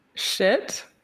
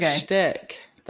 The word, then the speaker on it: shtick: Do you say shtick? – Shit? – Shtick. – Shit. – No. – You're giving me some shit about, but you don't give shtick. That's a whole shtick is how you say it.